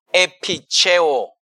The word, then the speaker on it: epicheo: It's the word epicheo, you know, you can spell it E-P-I-C-H-E-O, epicheo, you know, is the word that is used there.